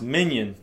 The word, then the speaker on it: minyan: And it's a word that is pronounced minion, and it's not like that show or that cartoon with the minions. But it's M-I-N-Y-A-N.